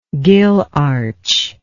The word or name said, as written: gill arch